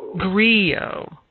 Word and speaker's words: griot: but is it geo? – Griot. Yeah, as it's like kind of like a French pronunciation.